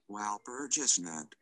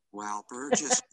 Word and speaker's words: Walpurgisnacht: Walpurgisnacht. – Hey, I was close. – Walpurgisnacht. Walpurgisnacht.